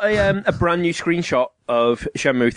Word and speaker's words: Shenmue: It's pronounced Shenmue.